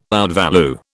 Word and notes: outvalue: British English

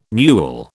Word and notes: newel: British English

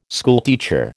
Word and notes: schoolteacher: American English

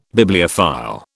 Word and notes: Bibliophile: British English

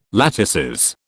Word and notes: latices: British English

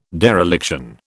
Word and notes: dereliction: British English

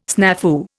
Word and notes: snafu: Indian

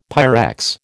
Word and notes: Pyrex: American English

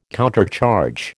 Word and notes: countercharge: American English

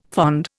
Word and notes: fond: Indian

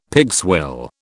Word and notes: pigswill: British English

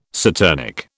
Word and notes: saturnic: British English

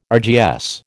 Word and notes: RGS: American English

